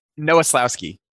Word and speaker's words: Nowoslawski: Nowoslawski.